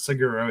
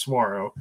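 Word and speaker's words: Saguaro: I've been told by family members that live in Arizona it is not Seguro, it is Swaro.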